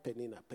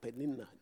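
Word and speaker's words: Peninnah: That's the way I think it should be pronounced. Not Penina, Penina. It's double N.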